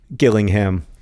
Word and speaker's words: Gillingham: Rosianna has just texted me to tell me that there is a third Gillingham. This one is also with a hard G.